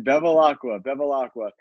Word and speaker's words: Bevilacqua: Am I saying that properly, Anthony? – Bevilacqua? Yeah, you got it, man. Bevilacqua.